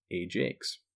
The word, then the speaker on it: Ajax: Now, you'll notice that that is pronounced Ajax, like J-A-K-E-S. But in France, either due to the accent or just the general French pronunciation of the word, Ajax is actually how they pronounce Ajax.